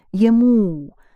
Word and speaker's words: ему: У is accented. Ему.